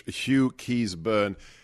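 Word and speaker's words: Hugh Keays-Byrne: Let me get his name right. Hughes Byrne. He has a double-barreled name. And that is, I don't know if you did any research, Hugh Keyes Byrne.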